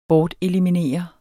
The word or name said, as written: BORTELIMINERER